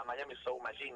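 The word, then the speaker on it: Miami Sound Machine: Tremendo que tocaba con la Miami Soul Machine, no sé si lo pronuncio sí, bien.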